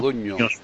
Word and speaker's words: Modugno: Tú le llamas Modugno, que es la pronunciación, sí, correcta en español, porque el GNS en italiano suena como la ñ.